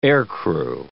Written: aircrew

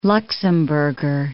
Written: Luxemburger

